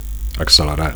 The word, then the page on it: accelerar: Catalan